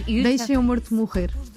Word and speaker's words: Deixa-me o Morto Morrer: There is a track called Deixa-me o Morto Morrer. Mm-hmm. Am I pronouncing that? Am I butchering that pronunciation? – It's in Portuguese, it's very hard to pronounce Portuguese, but it's almost perfect.